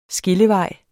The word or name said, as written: skillevej